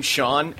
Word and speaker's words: Sean: First off, in Los Angeles, for whatever reason, no one knows how to pronounce my name, Sean, S-E-A-N. It's always Shin, Seen. Like, really? – You'd be surprised how many people don't know how to pronounce Sean.